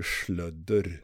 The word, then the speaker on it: Sludder: De, det rekommenderar varmt att ni kollar in Sludder från Karlstad. Jävlar vad bra. Grymt bra bandnamn också. – Mm. – Och det, det med värmländskt ö, så det blir ju slödder.